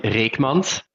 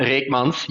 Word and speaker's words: Reckmans: So my last name would be in Dutch, it would be Yannick Reckmans. – Reckmans, yeah. – Reckmans, yes.